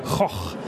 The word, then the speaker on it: Van Gogh: Also selbst das G spricht man als Ch aus, haben wir vor uns gelernt in der Ansprache. Choch. – Ja, aber als Franke geht das. – Van Gogh.